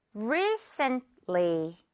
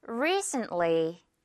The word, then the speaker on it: recently: 중간 T는요, 거의 안 들리게. recently. T는 정말 살짝 하거나 거의 안 들려도 돼요. recently.